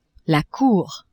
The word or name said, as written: cour